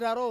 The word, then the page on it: raro: Ansus